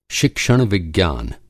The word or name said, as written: शिक्षण-विज्ञान